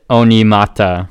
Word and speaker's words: Onyemata: The correct pronunciation is David Onyemata.